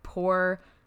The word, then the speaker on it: Porsche: He wrote, I said, do you pronounce it Porsche spelled the normal way or Porsche dash U-H-H-H? And he said Porsche. – He said what? – P-O-U-R dash S-H-H-H-H. So Porsche.